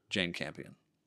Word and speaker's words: Jane Campion: And it would be down to Paul Thomas Anderson and Jane Campion.